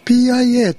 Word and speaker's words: PIA: でこれは 2020 年今年ですね、 8 月 23 日に PIA と書きますけど PI と発音して PI インターナショナルってところから刊行されている。